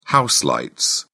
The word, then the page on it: house lights: British English